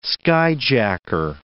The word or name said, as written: skyjacker